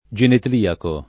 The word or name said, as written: genetliaco